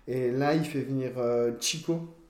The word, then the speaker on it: Cheeko: je pense Chico ça s'écrit C H I C O euh, non C H E E K O Ok, ah d'accord, Chico, ok d'accord.